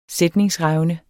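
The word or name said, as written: sætningsrevne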